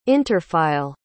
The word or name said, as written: interfile